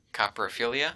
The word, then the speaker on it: coprophilia: how do you pronounce that? Ebot coprophilia. – It's actually called coprophilia, and let's actually look up where coprophilia comes from.